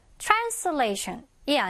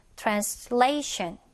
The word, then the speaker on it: translation: 그래서 여러분들이 이런 단어를 읽을 때는 션 앞에 오는 그 모음에다가 강세를 넣어주면 발음을 정확하게 할수 있죠. 그래서 얘도 pollution이 아니라 pollution이 되고요. 얘도 translation이 아니고요. translation이 되는 거죠. translation 바로 앞에.